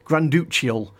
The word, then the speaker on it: Granduciel: Adam Granduciel, as I've been told it's pronounced. I've previously been pronouncing it very incorrectly.